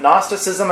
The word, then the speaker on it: Gnosticism: Gnosticism looks like Gnosticism, but the G is silent. Gnosticism, and I threw in there something called Docetism.